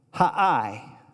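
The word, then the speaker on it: Ai: Haai, That's how that's pronounced.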